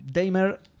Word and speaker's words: Daymare: Después estuve jugando Damer 1998 un juego sobre el que tenía que investigar y me olvidé. Porque. Eh, cómo se escribe? Eh, como Nightmare, pero de día. Ah, ok. Daimer 1998.